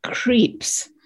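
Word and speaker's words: Krige: His partner, Chris, is played by Alice Creeps. It is pronounced that way, even though it's not spelled that way, I checked.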